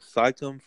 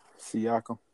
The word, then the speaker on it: Siakam: Saitum for having a breakout year. That nigga going crazy. – Siakam.